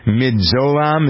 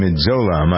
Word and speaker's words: midazolam: The justices decide that the sedative, how do you pronounce this, Kenny? Midzolam, is that it? M-I-D-A-Z-O-L-A-M, Midzolam, I believe.